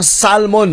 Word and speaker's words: salmon: Sinigang ulo sang salmon. May dagmay, may okra, may fresh beans. Halin sa hardin ko, yung sarap naman yan. Ulo ng salmon. Salmon. Salmon. Yung silent L mo.